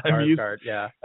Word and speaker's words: Sarsgaard: And Peter Sarsgaard is every time I say his name, I want to stick the K in there. – I know, Sarsgaard, I'm used to that.